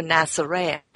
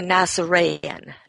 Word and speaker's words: Nasoraean: A priest of the Mandaean people is called a Nasarean. It's spelled Nasoraean. He was a Nasarean, not a Nazarene, as the New Testament tells you.